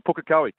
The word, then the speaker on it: Pukekohe: Are you a Pukekohe? – I'm at Pukekohe, correct. Yeah. Well done. Your pronunciation is bang on.